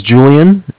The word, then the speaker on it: Julian: My name is Julian. It's J-U-L-I-A-N. – Uh-huh. – And I'm calling from Bank of Cardiff. It's spelled C-A-R.